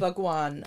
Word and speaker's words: Bhagwan: Bhagwan? Am I pronouncing it right? – Bhagwan.